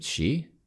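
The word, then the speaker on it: Shi: So Shai Smith, is it Shai? – She. – We should know this. He's been there forever. – Yeah. – Anyway, if you know this, send us a phonetic pronunciation. But it's S-H-I, Smith.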